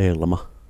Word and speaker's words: Elma: Elma, Elmi, Mitjo, Aleksi, Aleksis, ortodoksilla Aleksis tänään ja saamen kielessä Elma, mitenhän se lausutaan? – Sekin lausutaan vaan Elma.